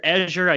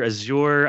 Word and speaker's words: Azure: And first off, I hear Azure, I hear Azure, I hear different pronunciations. Where does that come from? What's the right one? – I use Azure. Azure seems to be the predominant pronunciation, but I think depending where you are in the country, you tend to, you know, add a little flair to it.